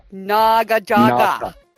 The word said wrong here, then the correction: naginata: And it's spelled exactly how it sounds. – Naginata. – There you go. Naginata.